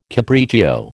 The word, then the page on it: capriccio: American English